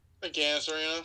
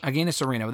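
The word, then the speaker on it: Agganis: is it a Gainus or a Gainus? How do you pronounce it? – A Gainus Arena. – A Gainus Arena.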